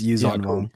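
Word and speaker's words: Yuuzhan Vong: It's Yuzan Vong.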